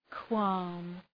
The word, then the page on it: qualm: kwɒm